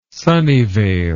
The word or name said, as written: Sunnyvale